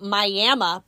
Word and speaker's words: Miami: They pronounce it Miami, but it's totally spelt Miami.